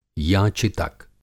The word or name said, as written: याचितक